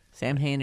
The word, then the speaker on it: Samhain: I know it is Samhain. – Samhain it is.